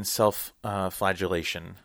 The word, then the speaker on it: self-flagellation: Now, we do see early on that Maude has an interest in self-flagellation, mm-hmm.